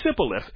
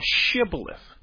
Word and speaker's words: shibboleth: They couldn't say shibboleth. Their accent would not allow them to say. It would say sibboleth instead of shibboleth.